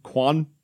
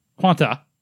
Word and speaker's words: Quanta: I like how it's the Gundam Quant. – Quanta is how it's pronounced.